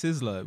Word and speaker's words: Sizzla: So, that is Sizzla? Is that how you pronounce his name? – It's Sizzla. Sizzla.